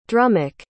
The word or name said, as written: drummock